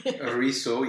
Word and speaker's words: ruisseau: So I wanted to start with Ruisseau. – Ruisseau, yes. – Only because when you look at this, it doesn't look anything like what it sounds like.